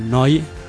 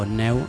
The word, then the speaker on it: Neu: También trabajó con Neu, o Neu, si lo pronunciamos literalmente tal como se escribe,